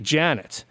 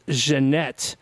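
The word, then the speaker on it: Ja'Net: her name is so her name is spelled like J-A-apostrophe-N-E-T. So I'm tempted to say Janet Dubois, but it's not. It's Jeanette or Jeanette. Yeah, I think it's Jeanette.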